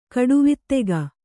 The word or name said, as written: ಕಟ್ಟವತ್ತಿಗೆ